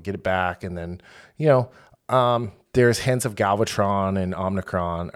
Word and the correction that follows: Unicron: Oh, wait, no, it's not. How do you say it? Is it Omnicron? – Unicron. – Unicron. Thank you.